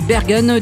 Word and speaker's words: Bergen: Bergen, du nom de la ville néerlandaise où il habite, située au nord d'Amsterdam, que l'on prononce Berge aux Pays-Bas.